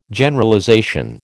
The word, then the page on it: Generalization: American English